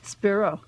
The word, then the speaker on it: Spiro: Spiro.